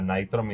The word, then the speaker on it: Nitrome: Y la novedad fue Shovel Knight Dig, que es un juego hecho con Nitrom, o Nitrome, ¿cómo lo pronuncias? – Nitrom. – Sí, qué bonito, Nitrom, uff.